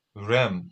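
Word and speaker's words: re'em: It says, the Hebrew Bible speaks of a rem, that's probably butchered it, R-E-apostrophe-E-M. – That's it, yeah.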